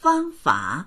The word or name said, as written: fang1fa3